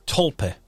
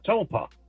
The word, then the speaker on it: tulpa: How do you pronounce it? – Tulpa. It's I think it's T-U-L-P-A, Tulpa.